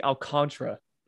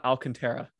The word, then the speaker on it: Alcantara: I've also kind of apologized to him because I always pronounce his last name wrong. It's Sandy Alcantara, not Alcantara, like a stupid gringo that I am.